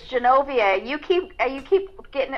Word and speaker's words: Genovia: It's Wachovia? Did I say it wrong again? – No, it's Genovia.